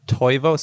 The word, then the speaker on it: Toivo: I'll be honest, I'm probably doing it wrong, but I think it's Toivo. – Toivo, so T-O-I-V-O, Toivo.